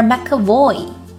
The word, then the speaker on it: McAvoy: McAvoy McAvoy 要注意重音的位置哦小